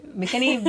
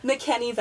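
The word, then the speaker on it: McKinney-Vento: Uh, you talked about, and I always get the, the, the way to say this wrong, the McKinley, McKinney Vento. – McKinney Vento.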